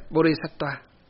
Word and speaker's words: Bodhisattva: Bồ Tát là, Bồ Tát là nguyên âm tiếng Phạn. Nguyên âm tiếng Phạn phát âm là Bodhisattva.